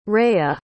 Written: reya

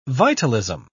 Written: vitalism

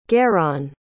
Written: geron

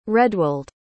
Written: redwald

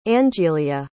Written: angilia

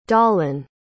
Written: dalen

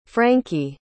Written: frankie

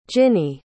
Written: jinnie

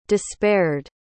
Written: despaired

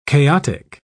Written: chaotic

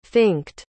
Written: finked

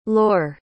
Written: laur

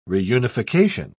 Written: reunification

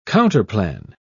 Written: counterplan

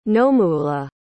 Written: nomuula

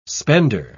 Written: spender

